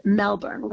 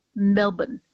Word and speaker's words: Melbourne: And she is like a thoroughly modern woman living her modern life on her own terms, but in 1920s Melbourne, which was, oh, I should say Melbourne, because I said it wrong.